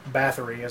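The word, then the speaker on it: Báthory: When you have a character, when you have Elizabeth Bathory, or Bathory, as they pronounce it in this.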